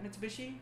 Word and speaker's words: Mitsubishi: Mitsubishi? – Yeah. – Mitsubishi.